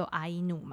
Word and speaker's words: 阿伊努: 只是我在网络上有查到啊，其实，在他们的名称，就像我们可能也会被揶揄说我们是山地人或什么的，他们也有类似的处境，像是埃努的发音就阿伊努嘛，就是他们的意思是说这是人的意思，但是其实呃日本人的呃日文的狗犬就是叫伊努，所以其实有时候。